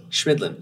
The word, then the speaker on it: Chemidlin: So like Schmidt, Chemidlin, and it took me a minute. But Chemidlin. – Chemidlin, yeah.